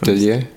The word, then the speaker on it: Tellier: I'm just gonna figure out how to say his name, but I don't know if Tellier? – That's how you say it? – Yeah.